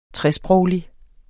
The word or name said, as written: tresproglig